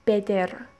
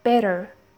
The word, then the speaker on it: better: یعنی در کلمه بتر نمیگیم بدر، یعنی د رو با شدت نمیگیم، د رو یواش میگیم better، د بدون شدت.